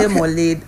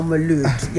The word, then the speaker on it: Mawlid: You can say Mawlid, Mawlid or Maulud.